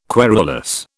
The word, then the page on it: querulous: British English